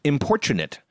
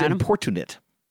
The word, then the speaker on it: importunate: It's importunate, so I thought it was importunate. – I would have thought that too, but it's importunate.